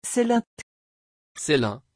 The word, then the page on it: ceylin: French